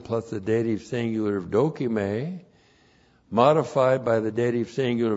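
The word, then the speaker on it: dokime (δοκιμή): D-O-K-I-M, long E, dokime. Point one, dokime means, first of all, testing someone for the purpose of approval.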